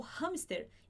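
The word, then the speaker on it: hámster: Outra palavra que também pronunciamos o H é o hamster, né? Aquele animalzinho fofinho é o hamster. Em espanhol a gente escreve assim e a gente vai pronunciar da seguinte forma. A gente fala hamster, hamster, ok? A gente pronuncia assim, hamster.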